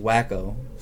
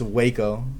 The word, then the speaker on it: Waco: And someone goes, it's pronounced Wacko. But like, nobody in Waco calls it Wacko, it's Waco.